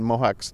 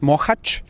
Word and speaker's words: Mohács: Now, what are the experiences in Mohács I'm pronouncing it incorrectly. – You're very close. Mohács. – Okay, Mohács. All right, Mohács.